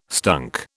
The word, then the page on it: stunk: British English